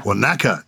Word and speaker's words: Wanaka: I'm an avid listener to your podcast from Wanaka, New Zealand. I wonder if I pronounced that correctly. – Wanaka. – Wanaka, New Zealand. – Wanaka.